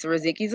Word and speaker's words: Riziki: Did I pronounce it right? – Yes, Riziki Zafira, you're correct.